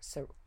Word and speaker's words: ceruse: So in the 1550s, they, like, started whitening their face with what you call ceruse, C-E-R-U-S-E, which I, like, literally had to Google how to pronounce, because I was, like, ceruse or ceruse. Anyway, it's ceruse, and that's made with vinegar and white lead.